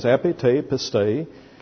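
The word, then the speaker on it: epite pistei (ἐπὶ τῇ πίστει): And here the expression is epite pistei, which would be translated E-P-I, epi, te, T-E, long E. You can use a little I after it if you like for the your subscript. And then P-I-S-T-E-I, epite pistei.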